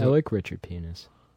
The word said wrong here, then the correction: Pinhas: It's Richard Pinhas.